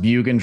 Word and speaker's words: Bjugan: So CodyBjugan, last name is pronounced Bjugan, drop the J.